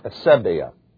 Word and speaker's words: asebeia: The word is spelled A-S-E-B E-I-A, pronounced Asebia.